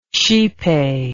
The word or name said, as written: xipe